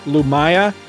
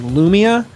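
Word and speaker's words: Lumiya: I, I've always said Lumaya. I believe I was corrected by Jo Duffy herself at a convention I met her at that it's actually Lumia, but I don't know.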